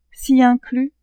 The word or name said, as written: ci-inclus